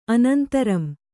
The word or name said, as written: ಅನಂತರಂ